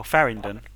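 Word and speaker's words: Faringdon: Am I pronouncing that right? – Oh, Farringdon.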